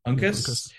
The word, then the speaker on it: Uncas: Uncas.